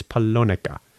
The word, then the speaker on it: Polonica: Polonia. – Well, even that's hard to pronounce. How do you find it? – Oh, I'm sorry, not Polonia. It's Polonica.